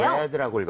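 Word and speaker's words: read: 슬기 씨가 처음에 read라고 했다가 뭐라 그랬죠? – red겠네요. – red라고 읽었어요.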